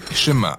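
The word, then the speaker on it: schéma (שמע): Le premier mot du schéma est écouter ou entendre, prononcé schéma en hébreu.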